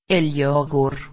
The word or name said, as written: el yogur